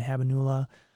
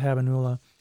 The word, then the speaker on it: habenula: And I think the second being withdrawal and that negative effects, that's more the amygdala and the habenula. – You said it right that time. First time for everything. – I'm always thinking habanero, but I'll go with habenula.